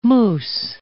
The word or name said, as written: moose